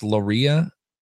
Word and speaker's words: Luria: How do you pronounce that? – I believe it's Loria. – Loria, huh? – Interesting. L-U-R-I-A is what Jeff Bundy is saying in the chat room.